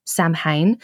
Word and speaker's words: Samhain: Now, Samhain is spelt S-A-M-H-A-I-N. So it looks like it would be pronounced Samhain, but it's pronounced Samhain.